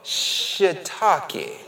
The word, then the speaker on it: shiitake: Loved ones, if you look at that phonetically, that S-H-I-I is shuh. Shiitake is how you say it.